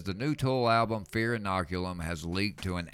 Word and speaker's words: Fear Inoculum: The new Tool album, Fear Inoculum. – Oh, my goodness. Hey, you can pronounce it. – Fear Inoculum.